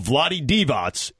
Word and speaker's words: Vlade Divac: And he texted me, tell Dan and Bojo your pronunciation of Vlade Divac is correct.